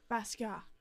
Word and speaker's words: Basquiat: I never pronounce Basquiat. – It right. Basquiat.